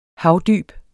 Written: havdyb

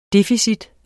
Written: deficit

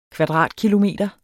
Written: kvadratkilometer

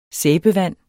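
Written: SÆBEVAND

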